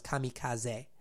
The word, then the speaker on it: kamikaze: The Chinese made a beachhead and were eventually wiped out by a tsunami that the Japanese called the kamikaze, or actually more properly pronounced kamikaze, which means what? – Divine wind or God's wind.